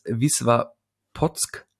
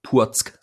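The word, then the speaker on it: Wisła Płock: Das heißt aber wohl Wisła Płotsk. Also dieses L äh, wird nicht als L ausgesprochen. Genau, das, das hatte ich ja gerade versucht auch zu machen, aber das, das CK ist kein K, sondern ein Sk. Wisła Potsk. Puock, sorry. Also äh, für alle Freunde des polnischen Fußballs.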